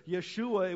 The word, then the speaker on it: Yeshua: In fact, the very name Jesus, Yeshua, it would have been pronounced in the Hebrew, means the Lord saves.